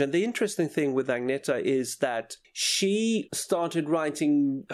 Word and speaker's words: Agnetha: Well, the correct Swedish pronunciation is Agneta.